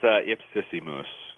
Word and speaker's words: Ipsissimus: That's Ipsissimus.